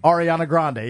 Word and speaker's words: Ariana Grande: Oh, Ariana Grande. However you say her name. – Ariana Grande. Yeah, you got it.